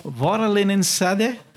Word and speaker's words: Vaarallinen sade: Vaarallinen sade? – Yeah. – Did I say it right? – Yes.